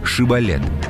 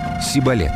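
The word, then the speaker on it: шибболет: Нужно было всего лишь произнести слово «шибалет», что в переводе означает «речной поток» или «колос». Ефремляне не могли безупречно выговорить требуемое. Они произносили «сибалет», и всякого, кого выдавал акцент, постигала казнь.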